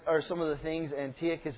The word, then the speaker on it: Antiochus: Antiochus. – I like that. It's good. You're pronouncing it right now. It sounds good. And Antiochus. No, I learned that from William. Antiochus Epiphanes the Fourth.